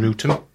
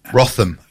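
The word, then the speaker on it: Wrotham: Yeah, we're based in Rootham. – In Rootham? – Yeah. – Okay. Good job Jules isn't here, because she'd never pronounce that. – Rotham. – According to her, it's Rotham.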